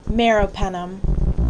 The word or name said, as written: Meropenem